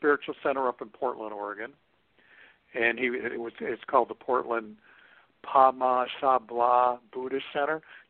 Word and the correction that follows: Padmasambhava: Not really, but that was close. Padmasambhava Buddhist Center.